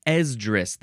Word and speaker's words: Esdras: Esdras. That's how you pronounce it. Esdras. With a capital E. ESDRAS.